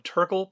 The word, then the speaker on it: Turkle: Before heading out to the Prime Hook beaches, check out the Refuge Visitor Center at the end of Turkle Pond Road. I am pronouncing that name correctly. Turkle, with a K, is just another way that some folks on Delmarva say the word turtle.